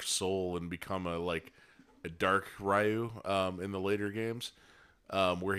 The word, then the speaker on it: Ryu: Yeah, I think actually the correct pronunciation is Ryu, but Americans say Ryu.